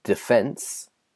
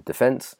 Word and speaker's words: defence: defence. We say in Britain, defence. Americans say defence. So they put the emphasis on the D, defence.